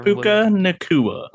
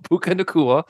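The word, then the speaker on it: Puka Nacua: I don't even know how to say the wide receiver's name, but Kyron Puka Nakua. – There you go. Puka Nakua, who a lot of folks picked up.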